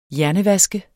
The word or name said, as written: hjernevask